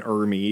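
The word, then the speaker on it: R. Lee Ermey: Because like when you said Fat Man, you said Arlie Ermey. Was that, is that how you pronounce that? – R, yeah, yep. R. Dot Lee, and then Ermey. Yeah, Ermey.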